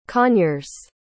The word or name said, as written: conyers